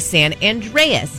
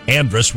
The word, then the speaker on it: San Andreas: San Andreas. – They've spelled it San Andres, which is wrong, and they've pronounced it Andres, which is wrong.